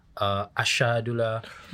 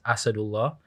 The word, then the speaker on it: Asadullah: So yeah, like so my proper pronunciation is Asadullah.